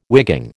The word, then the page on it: wigging: American English